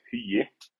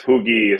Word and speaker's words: Hugi: So the right way to pronounce it in Icelandic is Hugi, but it tends to get lost in transliteration because, of course, Hugi is the more common one. So yeah, it's Hugi is fine.